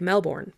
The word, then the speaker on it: Melbourne: which is spelled like Melbourne, but it's actually pronounced Melbourne, just saying.